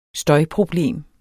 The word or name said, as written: støjproblem